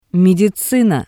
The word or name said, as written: mediczina